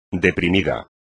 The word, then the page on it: deprimido: espagnol